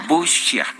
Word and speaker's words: Bucha: aquí en este programa Bucha. – Bucha. Bueno, ya me acuerdo que lo vimos ayer, pero se escribe Bucha eh, y estuvimos viendo la forma, parece que es Butsia la forma de pronunciarlo.